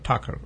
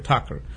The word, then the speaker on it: Thakur: Do you pronounce Thakur or Thakur? – Thakur. – Okay, Thakur.